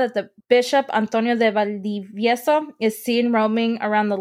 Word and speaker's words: Valdivieso: Valdivieso.